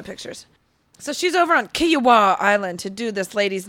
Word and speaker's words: Kiawah: How do you spell it? – K-I-A-W-A-H. Kiowa.